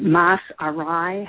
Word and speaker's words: Mas Arai: The way I describe it is Mas is more in Spanish, and Arai for things go awry.